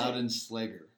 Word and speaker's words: Loudenslager: Loudenschlager.